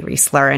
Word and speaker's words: Reisler: Um, you can also find me on Facebook, on Instagram, on Twitter, on LinkedIn, and it's all under my name, Julie Reisler.